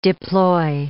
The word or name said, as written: deploy